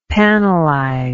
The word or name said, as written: panelized